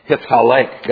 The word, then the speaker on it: hithalek: For those of you who know Hebrew, it is a hithpael form pronounced hithalek.